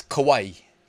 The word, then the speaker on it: Kawaii: I actually went to Google before I hit record, and I typed it in, then I hit the little enunciate icon, and it was Kawaii.